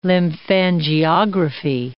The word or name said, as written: lymphangiography